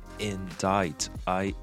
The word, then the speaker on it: indict: 美国的前总统川普呢，前阵子是被纽约的检方给起诉了。那么起诉的这个动词就叫做 indict，i